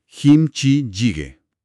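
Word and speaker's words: kimchi jjigae (김치찌개): En coreano, el plato se escribe e identifica como kimchi jjigae y su pronunciación es kimchi jjigae.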